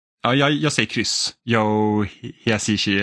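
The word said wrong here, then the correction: Hisaishi: eller vad man nu uttalar det. – Hisashi. – Och det är rätt.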